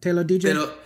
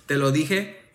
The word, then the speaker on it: te lo dije: I don't want to pronounce it wrong. Te lo dije? Is that te lo dije? – Te lo dije.